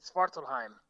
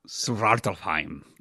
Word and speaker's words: Svartalfheim: Yeah, I think you roll the R a little bit. Svartalfheim. – Svartalfheim. So I got to say like a Viking.